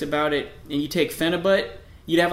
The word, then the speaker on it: Phenibut: So the Russians invented this compound called Phenibut. Phenibut. – Phenibut. – Phenibut. – I remember talking about this with you guys. – Yeah. It starts with the P. It's spelled P-H-E-N-I-B-U-T. Phenibut.